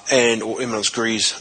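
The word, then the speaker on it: éminence grise: In Victoria, there's, you know, you've got the players, and then you've got the Eminence Gris. Or Eminence Gris, as my father, who's a French teacher, would tell me to pronounce it. But it sounds better as Eminence Gris, if you ask me.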